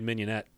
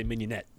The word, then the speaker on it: mignonette: I like, you know, a really good mignonette, basically. Mignonet is how it's pronounced in French.